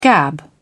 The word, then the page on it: gab: British English